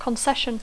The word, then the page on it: concession: English